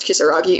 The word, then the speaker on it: Kisaragi: But apparently, although the name of the station is pronounced Kisaragi, it was written with the kanji for demon.